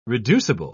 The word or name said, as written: reducible